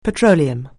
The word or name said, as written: petroleum